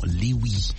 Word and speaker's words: Louis: Il y a beaucoup à dire sur cet artiste, en tout cas Louis Armstrong, un grand petit-fils d'ancien esclave, Louis, qui prononce volontiers son nom Louis à la mode Cajun, qui tend à l'écrire Louis avec L-O-U-I et E à la fin.